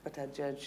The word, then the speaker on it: Buttigieg: You've got Pete Buttigieg, who's just awful. – That's good. You actually nailed it. The pronunciation.